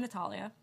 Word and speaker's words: Natalia: So hi, I'm Natalia. For those of you who don't know me, Natalia. People pronounce my name like Natalia, which I don't really care. I say Natalia,